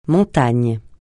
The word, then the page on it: montagne: francais